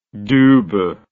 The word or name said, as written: dubhe